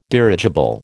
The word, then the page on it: dirigible: American English